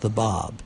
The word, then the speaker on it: Bab: And in his language, it's pronounced the Bab. The Bab means the gate. – It's B-A-B. – B-A-B, and it means the gate.